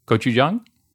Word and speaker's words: gochujang: Gochujang? No? – It's closer. It's better. Gochujang. – Okay. Next time you try to say idli sambar, I'll get on you on that one. I'm just kidding. Gochujang, I will try.